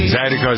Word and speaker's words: Zydeco: Zydeco, is that how you pronounce it? – Uh-huh.